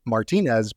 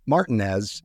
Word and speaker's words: Martinez: of course, you know, here in Georgia, we also have a town uh, called, I'm sure you're familiar with, Mike, uh, spelled like you would think it would be Martinez, but it's Martinez, Martin. Georgia.